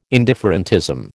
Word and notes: indifferentism: American English

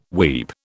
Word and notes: weep: British English

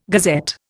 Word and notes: gazette: Indian

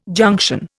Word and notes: junction: Indian

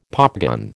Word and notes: popgun: American English

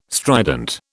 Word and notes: strident: British English

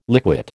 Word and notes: liquid: American English

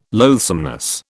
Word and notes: loathsomeness: British English

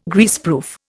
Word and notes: Greaseproof: Indian

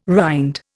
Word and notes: rind: Indian

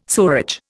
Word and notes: sewerage: Indian

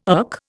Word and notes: erk: Indian

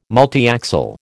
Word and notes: multiaxial: American English

